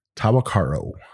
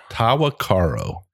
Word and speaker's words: Tahwahkaro: Tahwahkaro. – Tahwahkaro.